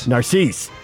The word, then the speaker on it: Narcisse: And that when they made the quarterback switch and they brought in Lowell Narcisse, how do you say that? – Narcisse.